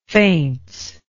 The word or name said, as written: faints